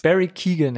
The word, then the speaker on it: Barry Keoghan: Barry Keoghan hätte ich jetzt gesagt. – Ja, klingt richtig.